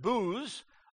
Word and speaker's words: Boaz: And Salmon begat Boaz, or they're pronounced Booz, of Rahab.